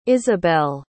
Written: Ysabelle

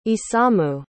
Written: isamu

